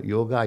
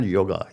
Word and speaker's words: yoga: But at the same time, you know, yoga is much more beyond that. In fact, I'm mispronouncing, it's not yoga, it's yog.